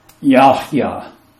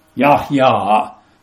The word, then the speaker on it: Yahya: So, Yahya. Yahya, because it's a long A.